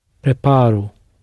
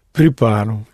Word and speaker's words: preparo: You go down on the penultimate syllable, and then you surface on an O, pronounced U, for the I form of the verb. So we had quero. So what would be I prepare? – Preparo. – Preparo.